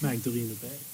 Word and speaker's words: Magdalena Bay: It's Magdalena Bay.